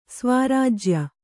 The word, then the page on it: ಸ್ವರಾಜ್ಯ: svarājya